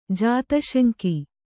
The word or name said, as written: ಜಾತಶಂಕಿ